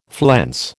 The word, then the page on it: flense: American English